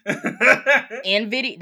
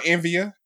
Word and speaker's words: Nvidia: Keep going, Envia. – Nah, it's Nvidia. That's how you say it.